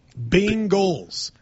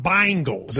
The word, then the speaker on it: Bengals: I say it like people in Cincinnati say it. Bengals, not Bengals. – There's no I. Then it should be B-I-N-G-A-L-S.